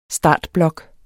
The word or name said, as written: startblok